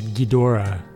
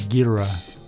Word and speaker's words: Ghidorah: I always tend to emphasize the first syllable. I said Ghidorah. He says Ghidorah.